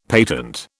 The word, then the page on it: patent: British English